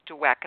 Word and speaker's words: Dweck: well, I also want to ask you about the work of psychologist Carol Dweck.